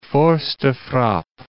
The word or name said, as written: force de frappe